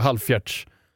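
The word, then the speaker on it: halvfjerds: Man brukar säga halvfjärts. – Fjärts? – Halvfjärts. Halvfjärts. – Jag har aldrig gjort en halvfjärts.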